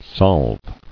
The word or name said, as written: Solving